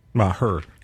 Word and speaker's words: Maher: Is it a one or two syllable name? He's one of those guys. Is it two syllables or one? Is it Mar or Maher? – I think it's two.